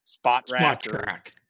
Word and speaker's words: Spotrac: And I always feel like I mispronounce this, Spotrac or Spot track. Okay, so you say the T twice.